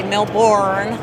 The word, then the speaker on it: Melbourne: Melbourne, Australia, yeah. Or as you Americans say, well, not you as an American, but other Americans say Melbourne.